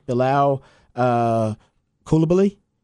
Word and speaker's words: Bilal Koulibaly: The Pacers actually drafted Bilal Koulibaly.